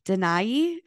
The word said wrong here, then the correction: Dena'ina: is that how you say it? – Denina, thank you. Denina lands in Anchorage, Alaska, home now.